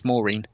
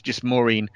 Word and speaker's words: Mourinho: Having worked with a couple of Portuguese people, the correct pronunciation of Mourinho is Maureen. Any Enio you don't pronounce. It's just Maureen.